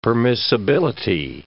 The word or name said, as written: permissibility